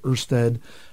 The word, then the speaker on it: Ørsted: Ørsted, you know, the company, you know, pronounces it Ørsted.